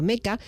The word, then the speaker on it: Mecha: La serie de manga Mazinger Z pertenece al género Mecha, escrito Mecha, aunque se pronuncia Mecha, que está dedicado al diminutivo de Mechanical.